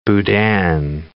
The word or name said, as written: boudin